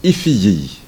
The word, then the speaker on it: Figig: Alors, vous savez, de Figig, et ici, Figig, on le prononce Ifiyi.